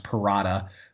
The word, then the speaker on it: Perrotta: So it's just it's my first name, which is A-N-D-R-E, Andre, and then my last name, which is pronounced Perrotta. So it's A-N-D-R-E-P-E-R-R-O-T-T-A 13.